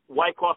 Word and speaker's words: Wyckoff: The city is called Wyckoff. I hope I'm saying right. Wyckoff? Wyckoff? How do you say? – Wyckoff, New Jersey. That's correct.